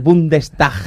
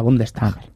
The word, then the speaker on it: Bundestag: Échate el Bundestag.